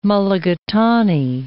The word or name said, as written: mulligatawny